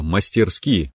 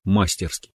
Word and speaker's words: мастерски: Правильно мастерски, а мы говорим мастерски.